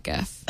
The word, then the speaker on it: GIF: GIF. – There's only one pronunciation that's correct. – Mm-hmm. – It's GIF.